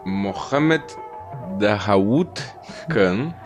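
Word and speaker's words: Mohammed Daoud Khan: A pronúncia correta é Mohammed Dawood Khan, que na verdade ele era primo do rei.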